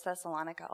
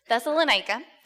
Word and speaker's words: Thessalonica: I learned that pronunciation. I thought it was Thessalonica all this time. Thessalonica.